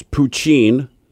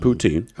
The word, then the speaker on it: poutine: It's poutine, not poutine.